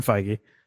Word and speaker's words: Feige: Okay, Kevin Feige.